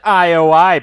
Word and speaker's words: IOI: which is pronounced IOI, by the way. Thank you thank you, Ernest, for telling me that. I would have pronounced it like yo or something.